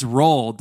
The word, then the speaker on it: Roald: The D is silent. It's Roald Dahl.